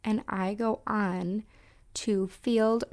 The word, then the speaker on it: Feeld: And then the other one is called Field. And it's spelled F-E-E-L-D, Field.